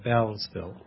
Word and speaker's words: Beelsville: That's actually pronounced Bellsville. – Bellsville. – Yes. – Beelsville. But, oh, really? How do they come up with that pronunciation? – I don't know. – It has an E in it. – Yeah. The founder's name is Beel, but everybody calls it Bell.